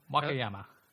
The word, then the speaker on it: Wakayama: So, Wakayama? I can never pronounce these things. It's just... – You and other languages don't get along. – No. – Wakayama.